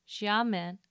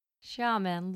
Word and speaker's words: Xiamen: which was in China in a place called Xiamen in the Fujian province. Thank you to my colleague Dr. Lin for helping me learn to pronounce these. Xiamen looks like an attractive seaside city.